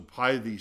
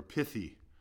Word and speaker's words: pithy: A parable was a pithy saying, or pithy, depending on how you pronounce it, P-I-T-H-Y.